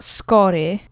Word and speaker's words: skóry: The accent goes on the penultimate syllable. Skóry. Skóry. Skóry. You just have to gently roll the R and the Y doesn't pronounce Y, skóry, or anything like that.